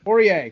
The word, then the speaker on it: Poirier: Lead into UFC 257, Conor McGregor versus Dustin Poirier? Is that how you pronounce it? – Poirier.